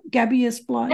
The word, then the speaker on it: Gabiasplice: Is what am I saying that right? Gabia Splice. – Yeah, that's perfect.